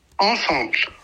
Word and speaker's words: ensemble: Ensemble.